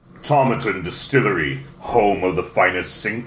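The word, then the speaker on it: Tomatin: Established in 1897, Tomaton, to rhyme with satin.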